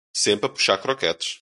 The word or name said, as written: puxar